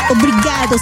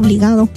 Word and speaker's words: obrigado: Obrigado significa gracias. – Buenas noches. – Porque van a pensar que estás diciendo obligado. – No, obrigado.